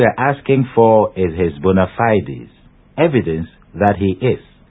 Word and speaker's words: bona fides: Finally, we draw attention to the expression bona fides, spelt B-O-N-A-F-I-D-E-S. – Bona fides. Note that the pronunciation of the second word, supposed to be the plural of fide, is fides, or bona fides, not bona fides.